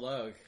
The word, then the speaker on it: Logue: I think he's currently, he plays on the, he plays Commissioner Gordon on the, one of the, Donald Logue.